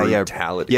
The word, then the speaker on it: brutality: weird because in that noun form, we actually do say the T as a T, right? – Yeah, brutality. – Yeah, because it begins the stressed syllable.